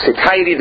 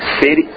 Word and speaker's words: satiety: Is it satiety? Am I pronouncing that correctly? Satiety? What? – Satiety. – No, satiety. There's a T-I in there. It can't be that.